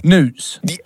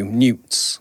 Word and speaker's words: newts: You're saying it like it's spelled N-O-O-T. – Newts. – Yes. – So think about old and new. Newts.